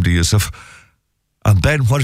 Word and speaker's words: Yusuf: And they know me as Yusuf. I chose my first name now. You know, Joseph was the name I chose. I turned it into an Arabic pronunciation.